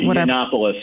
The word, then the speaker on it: Yiannopoulos: We're talking about Trump's meeting with Nick Fuentes and Kanye West and that other guy, Milo, how do you say it? Yalonus, whatever. What's his name, that guy's? – Yiannopoulos, yeah.